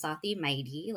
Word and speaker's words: Safi Maiti: It is pronounced Safi Maiti, like the word mighty.